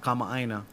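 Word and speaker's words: comma ina (kamaʻāina): If you look Asian and you just say comma ina, they're probably not going to say nothing and give you the discount. So you got to say it like that, though.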